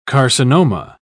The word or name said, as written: carcinoma